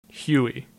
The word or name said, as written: Huey